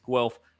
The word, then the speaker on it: Guelph: Ben did get the pronunciation correctly of Guelph correct in his first and last attempts. It's pronounced Guelph.